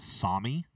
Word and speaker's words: Sami: Reindeer live up north, like northern Europe and Finland, and I'm going to go out on a limb and say this next word is Sami. Is that how would you say it? S-A-A-M-I? – It works.